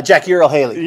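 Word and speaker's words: Jackie Earle Haley: Yeah, Jackie Earl Haley.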